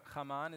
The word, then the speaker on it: Haman: let's say Haman, Haman in English, but Haman is how you'd say his name in Hebrew, the son of Agag, the Amalekite king, he came from them.